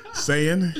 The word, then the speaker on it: Saiyan: Saiyan.